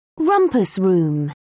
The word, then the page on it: rumpus room: British English